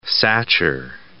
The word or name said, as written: Satcher